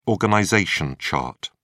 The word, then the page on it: organization chart: British English